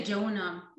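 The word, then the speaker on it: Joona: So the main character, do we say Juna? Is that how you would pronounce his name in Sweden? – Juna. We say Juna.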